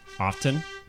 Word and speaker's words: often: Fucker, try O-F-T-E-N. – I knew you were going to bring that up. – Often? – No! – It is a silent T, I think.